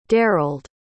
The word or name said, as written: derald